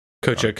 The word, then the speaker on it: Kotick: That was Bobby Kotick. – Kochik.